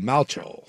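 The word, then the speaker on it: Malchow: They're saying it's pronounced like the Replacements quarterback Shane Falco, so Malcho. Malco.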